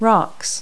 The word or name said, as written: rocks